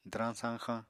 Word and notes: dwrañ-sañxañ: Apatani

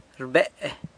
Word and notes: rbe: sanlucasquiavini

